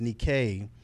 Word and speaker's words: Nike: Yeah, Nike actually means, it's pronounced Nike, and it's the goddess of victory.